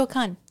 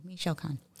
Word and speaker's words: Michoacán: Because you're going to say Michoacán. – Thank you. Michoacán.